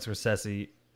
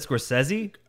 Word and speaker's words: Scorsese: Scorsese. How did he say his name? I always said Scorsese, but he said it like Scorsese, right? – Yeah, or something like that.